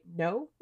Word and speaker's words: Noh: Um, and when we see Koh's face, it takes the forms of, I believe it's pronounced right, no masks, uh, spelled N O H.